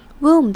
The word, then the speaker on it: womb: 然后 position 这边的 s 其实是发成有点像 z 的声音 z position position 的感觉，所以 fetus's position in the womb。最后我们来看 womb 这个字。 womb 的 b 不发音，然后这边的 o 发的是长母音 oo 的声音，像是汤的那个 soup 也是一样这种 oo 的感觉。那这边这个字的发音是 womb.